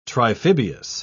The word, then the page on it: triphibious: British English